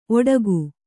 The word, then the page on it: ಓಡಿಕೆ: ōḍike